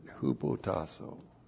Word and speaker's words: hupotasso: Great word, hupotasso. H-U-P-O-T-A-S-S, long O, hupotasso.